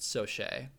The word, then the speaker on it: Saucier: Baseball pitcher Kevin Soche, which is spelled Saucier but pronounced Soche, who played from 1978 to 1982, was nicknamed Hot Sauce.